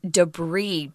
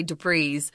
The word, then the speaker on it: debris: Notice it's kind of a weird pronunciation because it's French. And they just sometimes don't like to say that final S. So that's why we're saying debris. Please do not say debris.